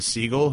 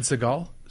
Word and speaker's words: Siegel: Steven Seagal? – Steven Siegel. – Yeah, fucking hates that. – It's spelled different. It's Siegel, motherfucker! – It's spelled differently. It's okay. S-I-E, thank God. – Yeah. It's Steven Siegel! – It's Steven with a P-H and Siegel with an S-I-E.